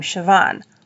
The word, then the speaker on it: Siobhan: Siobhan.